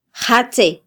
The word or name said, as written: jatze